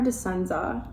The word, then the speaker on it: Dispenza: So this new Joe Dispenza podcast just dropped and I was like, is it Dispenza or Dispenza? – Dispenza. – Oh my God, I'm literally saying it wrong.